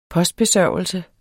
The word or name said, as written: postbesørgelse